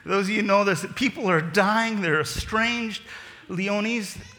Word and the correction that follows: Leontes: is that how you say it? Leon, hey, oh, hi, Caitlin. Yeah, Leontes.